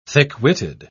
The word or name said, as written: thick-witted